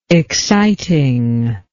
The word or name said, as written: excitingness